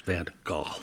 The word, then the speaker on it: Van Gogh: We will now, by the way, get many emails saying it's pronounced Van Gogh, not Golf.